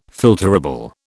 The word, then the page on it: filterable: British English